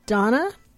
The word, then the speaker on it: Donna: I said, Donna? She said, yeah, I don't know how to spell that. And I said, it's D-O-N-N-A.